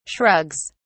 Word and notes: shrugs: British English

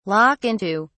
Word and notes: lock into: British English

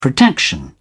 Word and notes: protection: British English

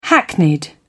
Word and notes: hackneyed: British English

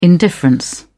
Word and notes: indifference: British English

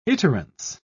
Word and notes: iterance: British English

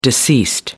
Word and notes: deceased: American English

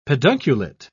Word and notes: pedunculate: British English

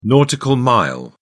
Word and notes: nautical mile: British English

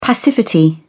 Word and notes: passivity: British English

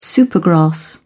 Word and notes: supergrass: British English

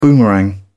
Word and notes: boomerang: British English

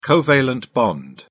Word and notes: covalent bond: British English

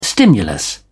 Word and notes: stimulus: British English

